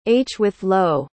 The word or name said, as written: hwithloew